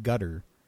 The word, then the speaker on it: Qatar: They're being, is it gutter? We pronounce it Qatar or Qatar, the country, Q-A-T-A-R. – Yes. – The Arabic, I believe, pronounces it gutter.